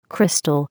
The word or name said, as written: crystal